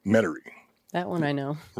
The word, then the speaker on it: Metairie: Think of the way not to pronounce it, and that's how they say it. – Ah, read it wrong. – Metairie.